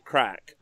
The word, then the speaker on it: craic: Now, uh, if anyone would like to read a good blog, uh, Plastic Crack, uh, although Crack is spelled C R A I C, Plastic Crack is a great uh, uh, Age of Sigmar blog if you want to go read it.